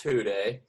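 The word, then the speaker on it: Who Dey: But it's pronounced Hude.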